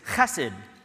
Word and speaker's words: chesed: The Hebrew word for kindness is chesed.